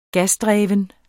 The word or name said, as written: gasdreven